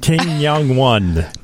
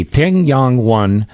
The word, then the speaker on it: Tiangong: I think it's pronounced Ting Yang 1. – T-I-A-N-G-O-N-G. Ting Yang 1.